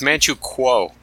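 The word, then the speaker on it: Manchukuo: Oh, that's Manchu Walk. My bad. – It's Manchu. – Manchu Walk. – It's Manchu Kuo. K-U-O. – Oh, Kuo. It should be Manjo Guo in like modern pronunciation.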